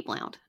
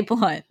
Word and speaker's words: Blount: It might be Blunt, but it's written as Blount, so it's going to be Blount. – You're from North Carolina. You pronounce it however you want to, boo. – Thanks. – We say Blunt, but we're also from Alabama, so take that as you will.